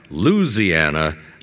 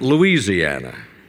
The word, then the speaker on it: Louisiana: That's because the name of the home state begins with L and is pronounced by Morton as a four-syllable word and by many others as five. Louisiana, not Louisiana.